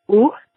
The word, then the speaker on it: eau: It's eau micellaire. So the O sound in French is kind of like the O in a word like cold. It's eau, eau.